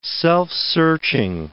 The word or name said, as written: self-searching